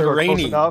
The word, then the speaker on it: Deryni: Dureni. D-E-R-Y-N-I.